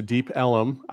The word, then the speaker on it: Deep Ellum: So, like I said, go out to Deep Ellum.